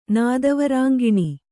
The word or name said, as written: ನಾದವರಾಂಗಿಣಿ